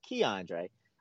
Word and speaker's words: K'Andre: whatever we want to call him, but it's Keandre.